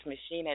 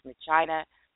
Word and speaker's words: Machina: This particular movie, Ex Machina, or someone told me it's not pronounced Machina, it's Machina, or something like that.